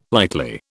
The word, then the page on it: lightly: British English